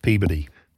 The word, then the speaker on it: Peabody: It's spelled Peabody, but it's pronounced Peabody.